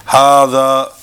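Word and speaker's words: hadha (هذا): Hadha is pronounced as it were written with an alif, hadha, and hadhihi is pronounced as if it were written with an alif, hadhihi.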